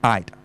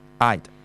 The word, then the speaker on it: aide: 大家呢個咧就係、是、就咁讀 aide 嚇、啊，即係嗰個 e 就唔發音嘅，臨尾個 e 就唔發音嘅 aide。